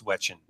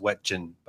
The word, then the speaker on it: Wetjen: It's Wetchen. – Wetchen. – Yep. – Okay, I'm looking at Wetchen, but it's Wetchen. – That's what I'm phonetically sure. It's Wetchen.